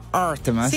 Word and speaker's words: Artemas: L'autore si chiama Artemas, sì, questa è la pronuncia corretta.